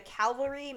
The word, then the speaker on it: Calvary: There is a study based out of Australia from the Calvary Mater Newcastle Hospital. Probably butchered the name of that. – No, Calvary's, that's correct.